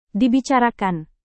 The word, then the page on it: dibicarakan: Indonesian